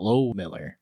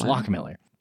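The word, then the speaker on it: Loughmiller: But it's pronounced Locke Miller. It's not Low Miller. It's Locke Miller. But you wouldn't know that by the spelling.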